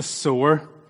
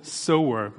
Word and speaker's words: sower: And I thought the parable of the sower would be wonderful. Sower. My wife has told me I need to pronounce it clearly because my Northern Irish accent. So I'm not talking about a sore arm.